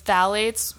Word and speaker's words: phthalates: So it's phthalates, which has a P in front of it, but the P is silent.